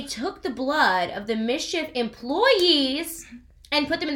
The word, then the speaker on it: MSCHF: Not only are they mad about that, he also made these shoes with a brand called, I think it's pronounced Mischief. It's like M-S-C-H-F. So, like, Mischief, whatever.